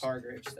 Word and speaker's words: Hargreaves: Hargreaves, that's how you say it.